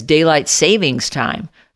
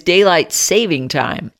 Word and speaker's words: daylight saving time: Now, for years, I always pronounced the time change as daylight savings time. Nope, no savings. It is daylight saving time.